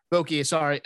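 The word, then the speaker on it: Vokey: I hit a couple Billy Volke. Is that how you pronounce it? Bill Volke? – Voki.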